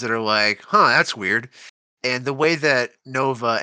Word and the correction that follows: Novoa: and then Eisenberg are playing off each other, or Novoa, I'm sorry, I mispronounced his name the whole time.